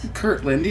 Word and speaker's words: Kirtland: Kirtland, yes. – But I feel like I'm saying it weird. – Railroad. – Okay. – I have to say it like it's two separate words.